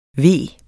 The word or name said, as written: veg